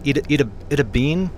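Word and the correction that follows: Itta Bena: Itabina. – Itabina. – Mm-hmm. That's what the natives call it.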